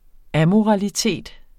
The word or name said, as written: AMORALITET